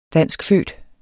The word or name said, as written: danskfødt